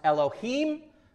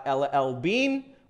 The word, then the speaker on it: Elohim: Understand that is pronounced Elohim, not El-El-Bean.